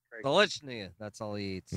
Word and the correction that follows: Stolichnaya: Pronounce this one more time. – Solichnaya. – Solichnaya. – Stolichnaya. Niche. – Stolichnaya. – There you go. You're a regular Ruski now.